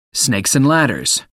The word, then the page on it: snakes and ladders: American English